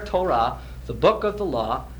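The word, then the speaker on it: Torah (תורה): That's a T and a long O and an R and an H sound, Torah.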